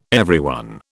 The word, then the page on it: everyone: British English